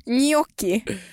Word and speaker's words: gnocchi: Nej, men här så har vi, jag har tagit fram här, proper pronunciation for gnocchi. – Alltså, gnocchi. Gnocchi. If you want to be Italian.